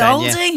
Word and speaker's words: Aldi: I say Aldi. – Yeah. And then Claire says Aldi all day, yeah. So you sound like you're saying Owl, though. Aldi. – Aldi. A-L. Aldi. – It's Aldi.